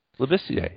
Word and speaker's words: Labissiere: Labissiere, yeah.